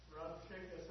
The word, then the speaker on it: Rabshakeh: And Rabshakeh said unto them, how do you pronounce that word? – Rabshakeh sounds good. – Sounds. Rib-shacky.